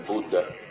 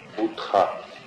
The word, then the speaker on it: Будда: И если вы помните, звук Х везде, он не читается. – Он не читается. Он как бы на придыхе. Поэтому мы говорим там Шримад Бхагаватам, мы говорим Будда. Хотя, если так дословно в русском, Будха. Будха. И поскольку Ха не читается, Будда.